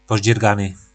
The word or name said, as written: pozdzyrgany